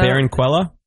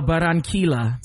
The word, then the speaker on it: Barranquilla: Barranquilla? – Okay. – No, Barranquilla.